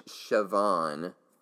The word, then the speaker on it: Siobhan: It's Siobhan Fallon Hogan. But in my defense, it is spelled S-I-O-B-H-A-N, which apparently, and I didn't know this, is the way that Irish people spell Siobhan.